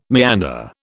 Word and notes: meander: British English

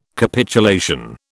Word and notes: Capitulation: British English

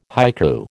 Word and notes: haiku: American English

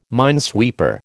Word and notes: minesweeper: American English

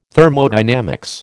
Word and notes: Thermodynamics: American English

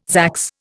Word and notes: zax: Indian

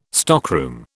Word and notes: stockroom: British English

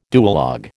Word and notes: duologue: American English

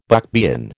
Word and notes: buckbean: American English

